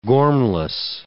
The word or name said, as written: gormless